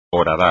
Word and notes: horadar: espagnol

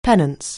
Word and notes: penance: anglais

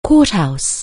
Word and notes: courthouse: anglais

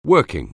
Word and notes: working: anglais

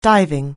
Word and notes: diving: anglais

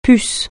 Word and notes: puce: francais

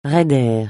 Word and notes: raider: francais

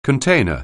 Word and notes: container: anglais

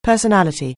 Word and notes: personality: anglais

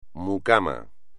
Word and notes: mucamo: espagnol

